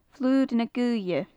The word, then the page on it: flur na goaithe: gaelic